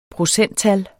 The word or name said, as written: procenttal